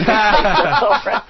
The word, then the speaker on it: plethora: The last time I was on, I mispronounced the word plethora and said plethora. – Oh, dear. No. – Jim looked like he'd been tasered when he heard plethora. But I decided to use it as my mantra now to be the official Stephanie Miller plethora pontificator. – All right. We will not disparage you again. – Hey, you said it right this time.